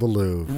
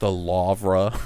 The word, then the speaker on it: Louvre: Pronounced the Louvre. – The Louvre. It's the L-O-U-V-R-A. – The Louvre. – It's like the Brett Favre.